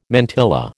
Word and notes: mantilla: American English

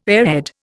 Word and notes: airhead: Indian

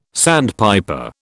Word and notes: sandpiper: British English

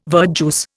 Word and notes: verjuice: Indian

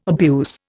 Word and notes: abuse: Indian

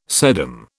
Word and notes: sedum: British English

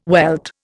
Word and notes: welt: Indian

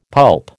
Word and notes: pulp: American English

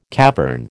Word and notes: cavern: American English

